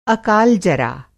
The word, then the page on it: अकाल-जरा: Hindi